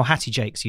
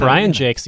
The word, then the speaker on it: Jacques: I pronounce it Jakes, which Jakes. Was how my grandmother pronounced it and how Hattie Jakes used to pronounce it. – And so, Brian Jakes, the author of Redwall, the book that inspired lots of awkward teen boys to join the military somehow.